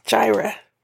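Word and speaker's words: Jireh: Yes, Gyra.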